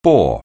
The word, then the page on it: bore: anglais